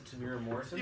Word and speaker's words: Temuera Morrison: that how you pronounce it, Temuera Morrison? Yeah, yeah, you're right.